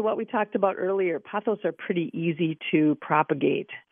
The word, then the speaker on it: pothos: This listener sent a text that said, I was recently in Florida and brought back, is it pronounced pothos? – Pothos vine, yep.